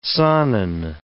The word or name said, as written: Saanen